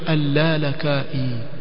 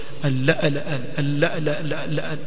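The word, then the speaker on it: al-Lalakai (اللالكائي): So, hawasikilizi ulama, hawakusoma chini ya wanaovioni na hawasikilizi wanaovioni wakisomesha, wasoma vipi ya majini hii, atamkwa vipi ili jina atamkwa al-Lalakai? Utawasikia wakisema al-la-la-la-la-la-la-la, yani yampaka kama kigugumizi mtu al-Lalakai.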